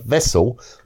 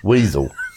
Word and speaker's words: Wesel: Now, whole towns, such as the communications hub of Wesel, were almost erased from the map. – I'm glad you said that, because that's not how I would have pronounced it. – Weasel.